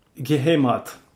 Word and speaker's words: Geheimrat: Et Klein, après avoir obtenu la plus prestigieuse marque de reconnaissance allemande, alors le GM-Rat, je ne sais pas comment on prononce ça, il insistait toujours pour se faire appeler par ce titre. Et du coup... – Geheimrat. – Ouais, bon voilà, quoi.